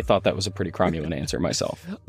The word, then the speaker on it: cromulent: So the word is cromulent.